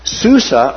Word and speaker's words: Susa: Susa or Shushan.